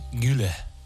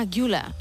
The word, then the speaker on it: Güler: Creo que Arda es Arda Güler. – Ah, Güler.